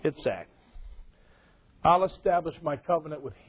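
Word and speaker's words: Yitzhak: and you shall call his name Isaac, which is pronounced Itzak.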